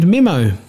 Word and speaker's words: Mimo: Well, they looked at me, and I was nicknamed Mimo, although it's spelt differently. It's not M-I-M-M-O, it's M-I-M-O.